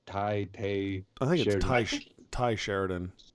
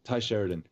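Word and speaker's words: Tye Sheridan: Tay Sheridan. – Tay Sheridan.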